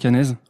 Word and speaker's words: Annese: On dit bien Franck Anaise Ouais, tu peux dire Anaise ou Anaisé, comme tu veux.